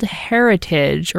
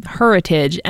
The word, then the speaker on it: Heritage: This coming Friday at Still Point in West Tisbury, there is an event called Heritage, or Heritage, emphasis on the her.